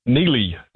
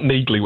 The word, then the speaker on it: Neely: It's the first spin-off from that monster action thriller series, and it focuses on his occasional partner, Neely, and it's not Neagly, which I thought it was as well, despite the hard G in her name. Neely. So there's a bit of a trap. I'm not sure why they did that, but that's her name.